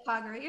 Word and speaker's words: Kagura: Yeah, it's Kagura. You're saying it right.